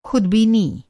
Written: ख़ुदबीनी

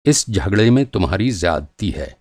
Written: ज़्यादती

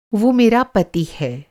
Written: पति

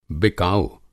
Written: बिकाऊ

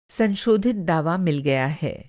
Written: संशोधित

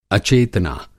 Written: अचेतना

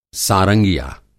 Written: सारंगिया

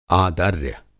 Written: आदर्य

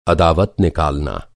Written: अदावत

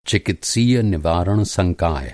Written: संकाय

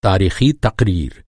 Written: तारीख़ी

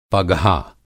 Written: पघा

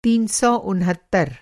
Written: तीन सौ उनहत्तर

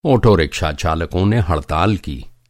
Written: ऑटोरिक्शा

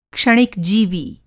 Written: क्षणिक जीवी

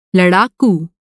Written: लड़ाकू

